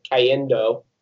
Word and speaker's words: Cayendo: Frank Ocean dropped two new songs, Dear April and Cayendo. Is that how you say it, Ty? – Yeah, basically.